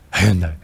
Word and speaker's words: Hyundai: Hyundai. You got to really like, the Y is different. – Hyundai executives told us should be smooth for customers to transition into buying on Amazon. So Hyundai is in the works to try to...